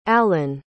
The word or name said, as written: alyn